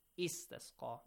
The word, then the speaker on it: استسقى: استسقى, you have to pronounce it استسقى, because the third letter is having a fatha.